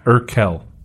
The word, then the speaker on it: Urquell: How do you say it? – Pilsner Urquell. – Urquell. – Mm-hmm.